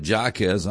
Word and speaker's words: Jarquez: Now, his name is spelled J-A-R-Q-U-E-Z. I'd pronounce it Jarquez. He'd pronounce it Jacquez.